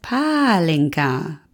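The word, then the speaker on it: Pálinka: Liebe euren Podcast, es heißt Palinka. Betont wird im Ungarischen immer die erste Silbe.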